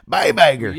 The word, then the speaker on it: Iger: Bob Iger.